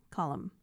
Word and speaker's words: Colm: And that's how you say it, right? I always wondered how you say his name. – Which one, the first or the last? – Colm. Okay. – I was saying it wrong, and then he told me.